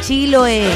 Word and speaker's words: Chiloé: Desde Keilen, esa hermosa y mágica isla grande de Chiloé. Chiloé. ¿Cómo lo dije bien o no? Ah, ya, perfecto. Recuerden, mensajes de audio.